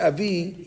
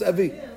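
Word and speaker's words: avi (אבי): When you say avi, you don't hear the yud. For all I know, there's a hidik under the bed, and it's avi. But maybe you want to make the yud pronounced avi. Yud. Don't do that. Anytime you have the letters Aleph, He, Vav, Yud at the end of the word, we don't bring it out.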